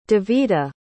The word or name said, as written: davitah